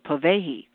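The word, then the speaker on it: Pōwehi: It's called Povehi, and it's spelled P-O-W-E-H-I.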